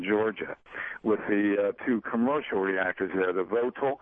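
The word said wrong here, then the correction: Vogtle: Vogel. The T is silent. – Vogel. – And then you don't pronounce the T.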